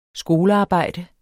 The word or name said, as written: skolearbejde